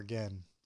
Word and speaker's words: 験: And if we look at this word, shugen, I want to look at the second character, which means it can be pronounced ken or gen, and it means trial.